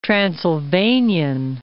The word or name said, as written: Transylvanian